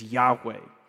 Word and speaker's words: Yahweh: The most traditional Hebrew pronunciation of this word, these two words, this one Hebrew word is Yahweh.